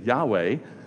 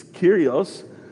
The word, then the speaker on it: Yahweh: So in Hebrew, we would say Yahweh or Yahweh. There's a number of different ways to kind of pronounce. It's difficult to pronounce because it's aspirated consonants.